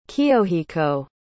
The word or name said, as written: Kiyohiko